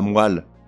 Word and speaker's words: moelle: On ne dit pas la moelle, mais la moelle, ou un ou une poêle,